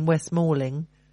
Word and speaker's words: West Malling: Okay, so there's a workshop at Churchill Square Business Centre in Kings Hill in West Malling.